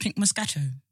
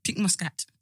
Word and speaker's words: Pink Moscato: Can we talk about this drink called Pink Moscato? – Oh, I love it.